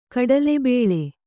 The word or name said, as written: ಕಡಲೆಬೇಳೆ